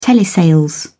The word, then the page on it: telesales: British English